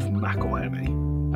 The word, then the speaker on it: McElhenney: It's Rob McElhenney. – It's McElhenney, isn't it? – No, he pronounces it himself, McElhenney.